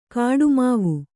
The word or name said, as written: ಕೊಡಮಾವು